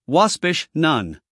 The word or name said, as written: waspish